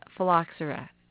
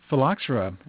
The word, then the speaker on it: phylloxera: What is phylloxera, and how do you spell it? – Well, phylloxera is spelled much like it sounds. It's P-H-Y-L-L-O-X-E-R-A.